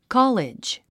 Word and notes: college: kɑ’lidʒ; カァレッジ

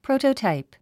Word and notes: prototype: próutətàip; プロゥタタイプ

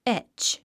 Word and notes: etch: étʃ; エェッチ

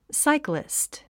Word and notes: cyclist: sáiklist; サイクリスト

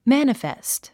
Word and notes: manifest: mǽnəfèst; マァニフェスト

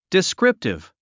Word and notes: descriptive: diskríptiv; ディスクリプティブ

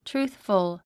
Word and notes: truthful: trúːθfəl; トゥルースフォ

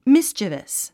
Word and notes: mischievous: místʃəvəs; ミスチヴァス

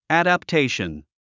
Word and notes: adaptation: æ`dəptéiʃən; アダプテイション

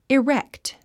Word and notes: erect: irékt; イレクト

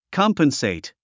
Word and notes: compensate: kɑ’mpənsèit; コンペンセェイト

